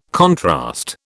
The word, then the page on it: contrast: British English